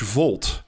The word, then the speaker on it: Volt: And it's a company called British Vault, V-O-L-T, because I've been accused of mispronouncing that in the past, by the way.